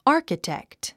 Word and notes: architect: ɑ’ː r kətèkt; アーキテクト

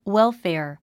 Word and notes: welfare: wélfèər; ウェルフェア

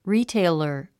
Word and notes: retailer: ríːteilər; リィテェラー

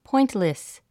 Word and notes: pointless: pɔ́intlis; ポイントレス

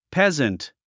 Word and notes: peasant: péznt; ペズント

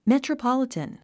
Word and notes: metropolitan: mètrəpάlit ə n; メトロポリタン

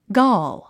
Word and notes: gall: gɔl; ゴル

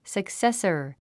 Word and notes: successor: səksésər; サクセサァ

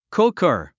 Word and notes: cooker: kúkər; クッカァ

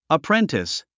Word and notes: apprentice: əpréntis; アプレンティス

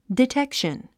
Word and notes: detection: ditékʃən; ディテクション

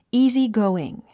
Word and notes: easygoing: íːzigóuiŋ; イージゴーイン

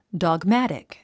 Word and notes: dogmatic: dɔːɡmǽtik; ドォグマァティック

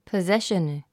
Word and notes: possession: pəzéʃən; ポゼション

